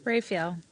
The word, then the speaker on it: Raphael: Raphael.